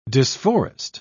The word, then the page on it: disforest: British English